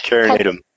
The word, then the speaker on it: Carinatum: You can treat this with a Nuspar, and then the pectus carnitatum. Pectus carnitum. – Carnitum. – How do you say it? – Carinatum.